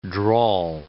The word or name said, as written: drawl